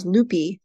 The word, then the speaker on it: Lupi: It was Lupi, not Lupe. They were like, yeah, this isn't right at all.